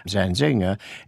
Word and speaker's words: Zanzinger: Zanzinger is stressed on the second syllable, but not on the final syllable.